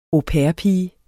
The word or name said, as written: au pair-pige